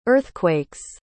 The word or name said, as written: earthquake's